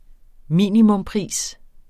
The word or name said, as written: minimumspris